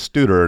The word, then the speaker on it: Studer: I think, you know, my last name is Studer, and it's spelled S-T-U-D-E-R. Well, you can imagine how many times it gets mispronounced stutter, which sounds like, you know, two T's instead of one D. The way that I say it is... you know, sounds like student, okay. As Studer, student, that kind of thing.